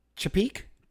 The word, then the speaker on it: Chapek: Chapek? – Yeah.